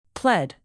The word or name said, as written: pled